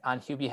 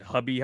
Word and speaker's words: Hubie: Any final thoughts on Hubie Halloween? – They kept changing the name. I'm pretty sure they pronounce it Hubby Halloween at least 4 or 5 times.